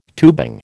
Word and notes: tubing: American English

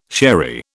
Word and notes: sherry: British English

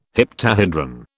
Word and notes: heptahedron: British English

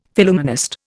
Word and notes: Phillumenist: Indian

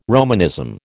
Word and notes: Romanism: American English

